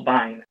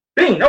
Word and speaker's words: Biehn: And he said, which is the wife of Terminator star Michael Biehn. So Biehn. – Okay. I'm sorry. Well, thank you for correcting me because I couldn't pronounce it.